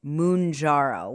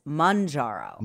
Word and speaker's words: Mounjaro: Okay, so Albert and Kyle pronounce it Mounjaro, which I prefer, but I saw the commercial. – Yeah, and what is it? – On TV. It's Manjaro. – Manjaro. – Well, like manja.